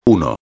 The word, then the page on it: 1: espanhol